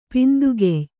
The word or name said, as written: ಪಿಂದುಗೆಯ್